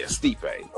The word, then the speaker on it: Stipe: Stipe Miocic. Yeah, he's Stipe, that's his name? – Yeah, Stipe.